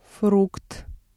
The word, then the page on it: фрукт: Russian